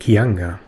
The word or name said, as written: kianga